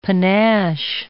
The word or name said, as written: panache